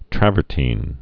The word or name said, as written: travertine